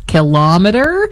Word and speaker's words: kilometer: Second syllable stress, kilometer. This style is very common in everyday Canadian speech for some reason, influenced by measuring devices like thermometer and speedometer.